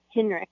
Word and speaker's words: Hinrichs: A lot of people struggle to say my last name, especially Hinrichs. It's like it's spelled with a CK, but it's actually spelled with a CH.